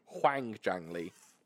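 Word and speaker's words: Hwang Jang Lee: Huang Jang Lee. – I've been saying Quang for like 3 years. – Oh, good. That's fine. It's Huang. – Yeah, it's Huang.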